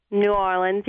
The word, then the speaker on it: New Orleans: Did I say that right? – New Orleans, yeah.